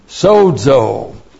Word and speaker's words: sozo: Sozo. Here's the Greek verb for salvation. S long O, Z long O. Sozo.